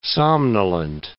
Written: somnolent

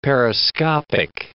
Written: periscopic

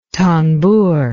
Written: tanbur

